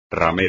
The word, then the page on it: ramera: espagnol